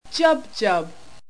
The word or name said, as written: jubjub